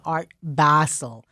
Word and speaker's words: Art Basel: And it is so important for people to pronounce it correctly because there is no better way to prove your ignorance and uncouthness than saying Art Basel. Basil is what you make pesto out of.